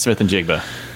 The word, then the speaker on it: Smith-Njigba: so we're going to be talking about Jaxon Smith-Njigba. Did I say that right? Yep. Njigba? – Jaxon Smith-Njigba.